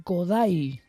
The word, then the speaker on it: Kodály: Bueno, empiezo hablando del compositor húngaro Zoltán Kodály, eso dicen ellos, se escribe Kodály, que nació hace 140 años, el 16 de diciembre de 1882.